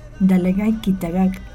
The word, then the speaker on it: Dalagay Kitagak: Este decreto de creación de la escuela bilingüe número 1333, Dalagay Kitagak, ¿lo dije bien? – Así es.